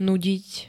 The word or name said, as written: nudiť